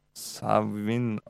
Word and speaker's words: Samhain: Ah, wir haben angeblich spricht man Samhain Savin aus.